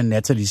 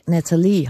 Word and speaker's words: Natalie: Og nu er hun jo altså også Natalie. Trump udtaler det jo sådan overvejende på fransk. Natalie Harp.